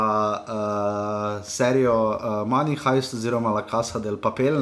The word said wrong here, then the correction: de Papel: ne, oziroma De Papel, se, ne, se to izgovori.